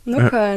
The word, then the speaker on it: Nockerl: Nockerl.